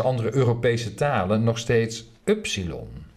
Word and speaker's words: upsilon: In het Oud-Grieks werd de naam van de letter dan ook als upsilon uitgesproken, maar in het modern Grieks werd het ypsilon.